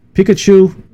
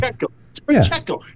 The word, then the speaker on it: Pacheco: Pikachu, I call him Pikachu. I can never pronounce his name from Kansas City. – Pacheco. It's not that hard.